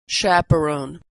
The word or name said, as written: chaperone